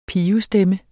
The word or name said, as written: pibestemme